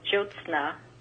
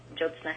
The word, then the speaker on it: Jyotsna: The pronunciation is Jyotsna. The Y is silent, Jyotsna Heckman.